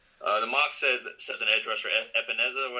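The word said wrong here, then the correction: Epenesa: whatever you pronounce it. Also see a linebacker Kenneth Murray. I hadn't heard a whole lot about him. – Yeah, I'll tell you again. Epinesa. – Epinesa, yeah, I'll go with you.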